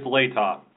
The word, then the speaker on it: Latah: It's Latah.